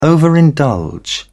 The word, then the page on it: overindulge: British English